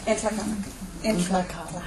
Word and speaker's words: Nlaka'pamux: Entlakala.